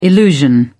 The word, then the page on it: illusion: British English